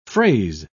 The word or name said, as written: fraise